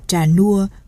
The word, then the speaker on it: Trà Nua: Xưa đường bộ Bạc Liêu đi xuống đến Vĩnh Châu là đụng biển hết đường, thật ra chữ nôm phải đọc là nua. Viết đủ là Trà Nua, đọc trại âm tiếng Khmer, chuội niều.